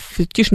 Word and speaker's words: фетиш: Вот, Джугашвилевский музей, да-да-да, вот, кстати, интересно, как бы вот от фамилии Джугашвили образовали подобного рода слово, не очень понятно, фетиш не французское, а португальское, говорит Дан Ле, не знаю, русский язык, оно пришло совершенно точно из французского, поэтому ударение на последний слог,